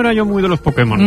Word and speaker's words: Pokémon: Para permitir que Putemon. – El poder que hay en Pokémon. – Putemon. – Hazte con solo. – Pues hazte con todos. Putemon. – Te diré que es difícil decirlo peor.